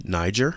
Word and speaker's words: Niger: It's a soft G, Niger.